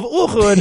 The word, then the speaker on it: Uhud: They're now talking about the Battle of Uhud I think that's that's how it's pronounced. Sure. Uhud.